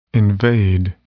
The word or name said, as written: invade